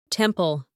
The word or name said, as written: temple